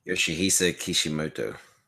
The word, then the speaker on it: Yoshihisa Kishimoto: And the creator, well, director, I don't know how to pronounce his name. Yoshida Kishimoto? – Yoshihisa Kishimoto.